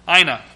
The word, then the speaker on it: Aina: A little girl was born, whom they named Anna. I can't say it right. Ina. There we go. Ina. A-I-N-A. Ina.